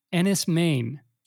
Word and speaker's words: Enys Men: First one is a film called, I'm going to try to pronounce it, how they pronounce it in an interview that I listened to. – Oh boy. – Enys, Maine, spelled Enys, space Men, which is apparently Cornish for Stone Island.